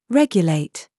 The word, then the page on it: YOU: British English